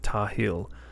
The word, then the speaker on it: Ptahil: It's pretty cool, but anyways, I think the P is silent at the beginning of this band name. It's Ptahil, and I'm pretty sure they pronounced it Ptahil. So that's how I'm going to pronounce it.